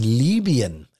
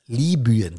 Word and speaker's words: Libyen: Immer noch im September Sturmtief Daniel in Libyen, Libyen, so wird es ausgesprochen, Hafenstadt Darna komplett verwüstet.